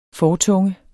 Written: fortunge

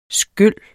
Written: skyl

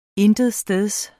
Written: intetsteds

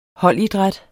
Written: holdidræt

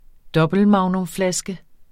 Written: dobbeltmagnumflaske

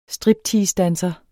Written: stripteasedanser

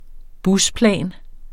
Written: busplan

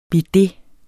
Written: bidet

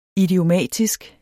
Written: idiomatisk

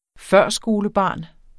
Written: førskolebarn

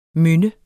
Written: mynde

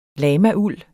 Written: lamauld

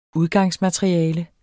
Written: udgangsmateriale

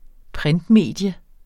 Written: printmedie

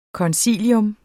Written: koncilium

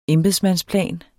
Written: embedsmandsplan

